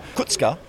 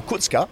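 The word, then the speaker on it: Kučka: When Tufan came on, it meant Sissoko and Kutska, as I've been told by Slovakian, is how you say his name, Kutska.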